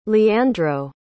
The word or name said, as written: leandro